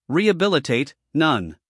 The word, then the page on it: rehabilitate: ˌriːəˈbɪlɪteɪt